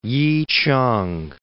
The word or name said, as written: Yichang